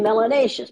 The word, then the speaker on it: melanaceous: If you have more melanin, you must be melanaceous. Melanaceous, which rhymes with gracious and spacious and efficacious.